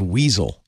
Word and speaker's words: WSL: You know, Richard Stallman this week said WSL should be pronounced weasel.